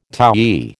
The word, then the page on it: towy: American English